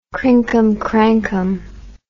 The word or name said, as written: crinkumcrankum